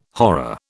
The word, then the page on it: horror: British English